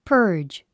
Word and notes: purge: pə́ːrdʒ; パージ

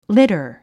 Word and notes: litter: lítə r; リタァ

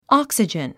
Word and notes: oxygen: άksidʒən; オォクスィジェン

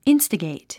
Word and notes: instigate: ínstəgèit; インスタゲイト

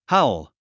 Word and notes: howl: hǽul; ハウル

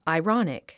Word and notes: ironic: airάnik; アイロォニック

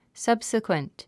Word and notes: subsequent: sʌ́bsikwənt; サブスィクエント

